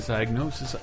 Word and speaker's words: Psygnosis: It's like some bizarre indie game idea that probably came out of Psygnosis or something, or Psygnosis, however they pronounce it. – Psygnosis.